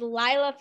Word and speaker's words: Lilah: All of my social media is Lilah Fitzgerald. If my name is spelled L-I-L-A-H, kind of different than how most people do it.